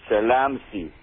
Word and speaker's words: Zell am See: And he said, as they both, the word Zell am See is pronounced by the people who come there as Salam See, or Salam for the word peace.